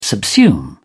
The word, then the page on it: subsume: British English